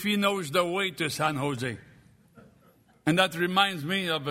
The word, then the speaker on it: San Jose: And the man corrected her, and he said, the J is pronounced like an H. We say San Jose.